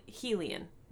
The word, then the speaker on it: Helion: Sarah J. Maas pronounces his name as Hel- Helion, not which is Hellion, wrong, which is wrong.